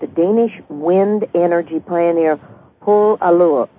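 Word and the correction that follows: Poul la Cour: Lecour. Yes. – Lecour. Paul Lecour.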